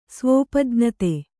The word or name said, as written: ಸ್ವೋಪಜ್ಞತೆ